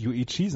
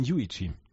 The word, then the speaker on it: Yuichi: Und Yuichi Sugita, da ist ganz wichtig, dass die Betonung auf der ersten Silbe ist vom Vornamen. Er heißt nämlich nicht Yuichi, sondern Yuichi.